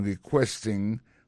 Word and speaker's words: requesting: Dans mot requesting là, ouais, accent tombé sur deuxième syllabe là, requesting, politely.